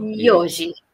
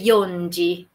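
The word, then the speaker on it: Yoji (四時): Yoji. No dice yon-ji. Y cualquiera diría, y debe ser que la antes de la G, pero con Sanji lo dicen. – Vale, Yoji.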